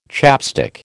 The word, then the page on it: chapstick: American English